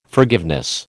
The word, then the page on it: forgiveness: American English